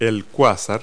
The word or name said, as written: quasar